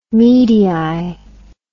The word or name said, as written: medii